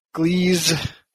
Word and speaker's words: Gliese: So you arrive at the very far fringes of Galactic Central Point and are heading into the Gliese Astronomical Cartography Institute. – Can you spell that? Can you spell that? – G-L-I-E-S-E.